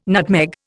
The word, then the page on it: nutmeg: Indian